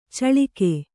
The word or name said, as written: ಚಳಿಕೆ